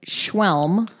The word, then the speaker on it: Schwelm: It says police in the western town of Schwelm. That's the town's name. Look, if you want to see how it's spelled, you tell me how to pronounce that. – Schwelm.